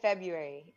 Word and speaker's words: February: So it's not February. No. – February. – R comes after the B.